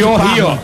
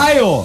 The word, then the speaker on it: Ohio: Ohio. – Parma na Itália. – Em Ohio. Parma no, em Ohio. – Deve é verdade. – Que o H tá é mudo, né? – É verdade.